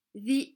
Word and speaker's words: the: The English school is in England. The se dit the avec la langue entre les dents.